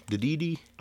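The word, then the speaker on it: Dedede: DDD? – I would say DDD. In fact, that's how I will be saying it, because every other way sounds goofy.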